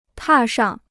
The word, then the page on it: 踏上: tà shàng